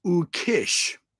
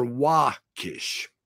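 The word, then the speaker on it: Ukix: His pronunciation is Ukish or Wakish.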